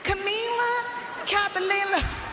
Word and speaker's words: Camila Cabello: Not Camila Cabello. Not Camilla Cabello. – Anyways, Camila Cabello. Cabello.